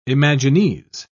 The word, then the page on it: imagines: British English